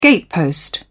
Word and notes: gatepost: British English

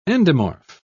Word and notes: endomorph: British English

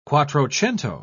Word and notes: quattrocento: British English

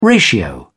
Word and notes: ratio: British English

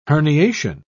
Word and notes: herniation: British English

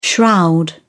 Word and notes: shroud: British English

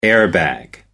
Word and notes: air bag: British English

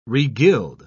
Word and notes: regild: British English

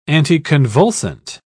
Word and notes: anticonvulsant: British English

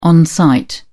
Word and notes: on-site: British English

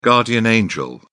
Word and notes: guardian angel: British English

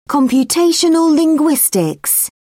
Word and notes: computational linguistics: British English

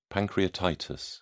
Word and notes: pancreatitis: British English

